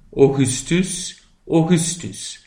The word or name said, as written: augustus